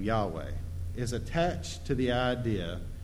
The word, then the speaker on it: Yahweh: In the text, God's name is represented by four Hebrew letters that, to the best of our approximation, is pronounced Yahweh.